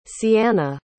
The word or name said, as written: ceanna